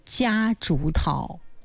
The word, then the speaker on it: 夹竹桃: 我也看到过红色，因为我就滁州开种这种花的比较多啊，它就是夹竹桃。这个字在这儿读夹，第一声啊。一开始的时候我们有的读夹竹桃，因为它是一个多音字，但是在这个植物当中它的读音就是夹竹桃。